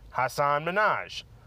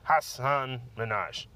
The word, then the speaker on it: Hasan Minhaj: I'm saying Hasan Minhaj in a very particular way, because I know he chose Ellen of all places to correct people on how to pronounce his name, because everyone was saying Hasan Minhaj. Well, it's Hasan Minhaj.